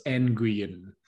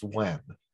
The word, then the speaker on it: Nguyen: Because I whenever I see it, I'm like, as Nguyen. – I believe it's pronounced, Wen. – Wen. Okay. – I believe it could be Nguyen, but I think it's Wen. – Well, it's definitely not Nguyen.